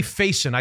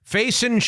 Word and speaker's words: Faison: His name is Jeremy Faison. I think that's the way you pronounce it. – Okay. – F-A-I-S-O-N.